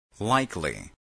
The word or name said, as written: likely